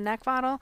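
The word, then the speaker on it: Neckvatal: You can also find me on Twitter, LinkedIn, and YouTube under my name, Brenda Neckvottle. Last name is spelled N-E-C-K, like the thing you want to choke.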